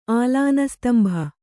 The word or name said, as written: ಆಲಾನಸ್ತಂಭ